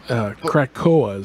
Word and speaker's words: Krakoa: Krakoa is how I pronounce Krakoa. – K-R-A-K-O-A. – There you go, Krakoa.